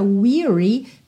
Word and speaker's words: weary: Atenção à pronúncia dessa palavra weary, porque se a gente tira o Y, a gente tem a palavra wear, como de usar uma roupa, ok? Wear, mas aqui a gente tem então essa pronúncia fechadinha, weary, ok?